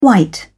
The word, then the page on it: white: British English